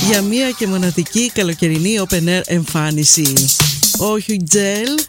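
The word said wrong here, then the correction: Hugel: ο Ουζέλ, έτσι προφέρεται, έρχεται στο Θέατρο Βράχων και ευκαιρία να έρθετε κι εσείς Αθήνα να τον δείτε από κοντά.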